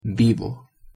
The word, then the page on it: vivo: Spanish